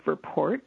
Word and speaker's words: Verpoort: And my name is spelled Monique, M-O-N-I-Q-U-E, and my last name is Verpoort, so V as in Victor, E-R as in Robert, P as in Paul, O-O-R as in Robert, T as in Tom.